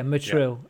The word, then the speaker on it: Motril: Motril, is it? – Yeah, Motril.